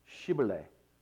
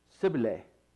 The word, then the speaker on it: Shibboleth: It's actually pronounced Shibboleth. Shibboleth means flowing stream. And the closest that the Ephraimites could come to was Sibboleth.